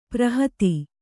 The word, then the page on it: ಪೌರೋಹಿತ: Kannada